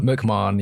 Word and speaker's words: McMahon: Is your last name, how do you say your last name? Is it McMahon? – McMahon, yeah, that's McMahon. Accurate.